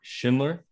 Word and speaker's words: Schindler: His name is Mark Schindler. I say that right? – Yeah, that's correct, man. Just like the list. – Just like the list, Schindler's list.